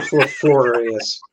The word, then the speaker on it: fortuitous: I know, and you can't even pronounce it. – It's super fortuitous. – There you go.